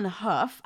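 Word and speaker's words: Hough: You have Puff on Huff. I guess that's good for Julianne Huff because she's reminding people how to pronounce her name correctly.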